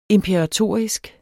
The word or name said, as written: imperatorisk